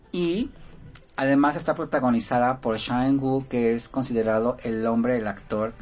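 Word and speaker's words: Cha Eun-woo: Shang-woo se escribe Sha, como de cha-cha-cha. E-U-N. Ajá. Woo con doble O.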